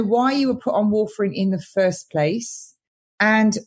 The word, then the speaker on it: warfarin: David asks, do you have an alternative to, I hope I say this right, warfarin? – Warfarin.